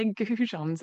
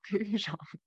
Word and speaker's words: goujons: And it turns out that they're goujons and not coujon. I mean, that's not even a mispronunciation, because that's actually a different letter.